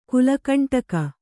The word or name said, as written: ಕುಲಕಂಟಕ